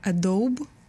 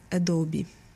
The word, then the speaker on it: Adobe: и некоторые называют эту компанию Adobe, но правильно произносится она Adobe.